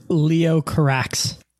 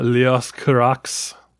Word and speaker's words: Leos Carax: Is it Leos or Leo? And the S is silent. – I'd say Leo Carax. – Okay. It's not Leos Carax.